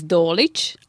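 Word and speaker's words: Dolić: So my maiden name is Dolic. It's spelled D-O-L-I-C, but it's finishing with ich.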